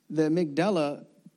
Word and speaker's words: amygdala: It's the amygdala. Did I pronounce that right? Amygdala. There you go.